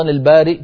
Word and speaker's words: البارئ: كذلك سؤالك الثاني، كيف نقف على قوله تعالى في سورة الحشر هو الله الخالق البارئ المصور، أيضا البارئ كتبت الهمزة على ياء فنقف عليها على همزة والياء كرسي